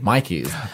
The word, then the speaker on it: Myki: So in Melbourne, we in our public transport system, the tickets you use are called Mikey's, but it's spelled M-Y-K-I.